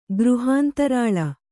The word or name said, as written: ಗೃಹಾಂತರಾಳ